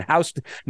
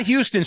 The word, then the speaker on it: Houston: The neighborhood's changed down there on Houston – not Houston Street, Danny, Houston Street. – Houston, you know, yeah. – Even though it's spelled Houston – it's pronounced Houston's.